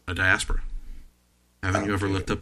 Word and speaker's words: Diaspora: Also, the fact that people kept mispronouncing it drove me crazy. I don't know about you, but Diaspora sounds like a medicine. – Mm-hmm. – No, people would always mispronounce it Diaspora. It sounds like a medication. – Yep. – The term is Diaspora, and I take my Diaspora very seriously.